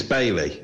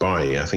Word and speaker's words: Bailey: I think it's Bailey because he's Jamaican, isn't he? And I don't think it's pronounced Baye. Normally I would question you calling him Bailey, but I think he really is called Bailey.